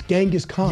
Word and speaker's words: Genghis: I say Genghis. – Author boy. – guy writes a few books, and he thinks he knows how to pronounce Genghis. – That worked out for me. – So the thing is, I'm right. It's Genghis Khan.